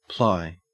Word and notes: ply: Australian English

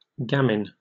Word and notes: gamin: British English

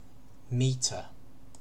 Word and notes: metre: British English

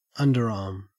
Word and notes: underarm: Australian English

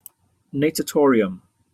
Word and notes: natatorium: British English

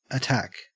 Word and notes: attack: Australian English

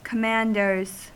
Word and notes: commanders: American English

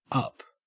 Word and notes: up: Australian English

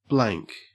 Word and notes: blank: Australian English

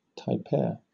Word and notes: tapir: British English

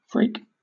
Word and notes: freak: British English

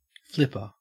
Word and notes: flipper: Australian English

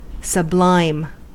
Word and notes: sublime: American English

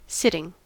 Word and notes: sitting: American English

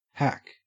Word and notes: hack: Australian English

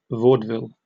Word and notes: vaudeville: British English

